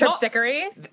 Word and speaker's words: Terpsichore: The pronunciation's horrible, but she's Terpsichore. – Thank you.